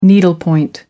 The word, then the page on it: needlepoint: American English